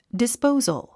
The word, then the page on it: disposal: dispóuzəl; ディスポウザル